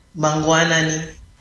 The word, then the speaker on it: Mangwanani: So how do you say good morning in Shona? – Mangwanani. – Mangwanani? – Yes. – Mangwanani? – Yep. – Okay. – You got it. – Mangwanani.